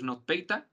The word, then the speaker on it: NotPetya: Ah, me trago mucho con ese nombre. Es ya, pero es NotPeta. Vamos, pe, pues Ya digo, me trago, pero bueno, se escribe N-O-T-P-T-Y-A, ¿vale?